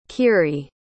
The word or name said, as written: khiry